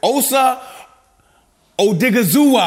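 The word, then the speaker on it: Osa Odighizuwa: Please welcome back friend of the show, Cowboys defensive tackle, Osa Odigizuwa. I hope I said it right, big go. – Dog. – Yo, there you go.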